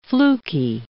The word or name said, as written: fluky